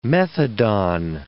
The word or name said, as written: methadon